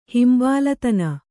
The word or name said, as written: ಹಿಂಬಾಲತನ